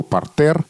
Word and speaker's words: партер: И у нас были до этого то, что называется орфографические прецеденты, например, слово «партер» произносится твердо, но пишется «е».